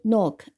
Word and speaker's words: knock: 这个词 knock 拼写非常简单，但我们必须记住，这里是 k n o 发成 no，那么 k n 发成嗯，这个 k 你可以当它不发音，或者是说你可以当它是 k n 发成嗯，加上 o no，再加上 c k knock，所以这个拼写是 k n o c k knock。